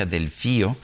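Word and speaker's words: Delfeayo: Su hermano del fallo, del, del fallo Ah, me dice aquí se pronuncia Delfío, yo pensaba que era del fallo, en fin, Fío, un gran trombonista, y su hermano Jason, un gran baterista, todos de jazz.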